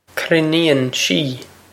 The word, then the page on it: cruinníonn sí: krin-een she